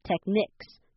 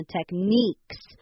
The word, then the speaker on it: techniques: 好，那还有再来看到后面那个字 techniques，好，这不要念成 tech，是 tech，最重要的是后面那个 ni，你千万不要念成 techniques，这样是不对的。好，你要念成 techniques，这样才是对的哦。